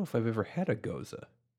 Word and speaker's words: Gose: I've heard it pronounced goza. G-O-S-E. – It probably is.